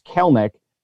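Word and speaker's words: Kelenic: And by the way, I was alerted that I've been mispronouncing his name rather than Kelnick. It's Kelnick.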